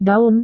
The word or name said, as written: daun